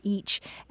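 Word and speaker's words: ic: In Old English, the word for I was spelled I-C and pronounced itch or each.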